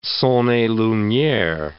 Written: son et lumiere

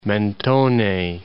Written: Mentone

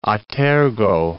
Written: a tergo

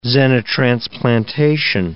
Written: xenotransplantation

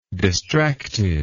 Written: distractedness

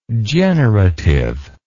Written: generativeness

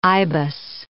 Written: ibis

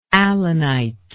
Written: allanite

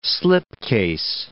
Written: slipcase

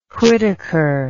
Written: whitaker

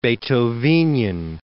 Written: Beethovenian

